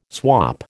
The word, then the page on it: swop: American English